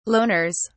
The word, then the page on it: loners: British English